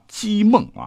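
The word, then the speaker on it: 剧孟: 他叫姬梦啊，这个姓比较少啊，是剧喜剧的剧啊，但是要念成姬，是当时的洛阳一带有名的侠客了哈，常常是爱打抱不平。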